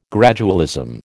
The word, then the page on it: gradualism: American English